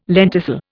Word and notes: lenticel: Indian

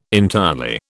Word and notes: entirely: British English

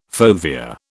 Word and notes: fovea: British English

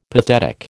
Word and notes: pathetic: American English

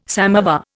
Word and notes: samovar: Indian

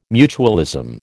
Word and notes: mutualism: American English

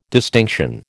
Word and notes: distinction: American English

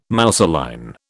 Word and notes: mousseline: British English

